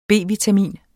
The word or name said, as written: B-vitamin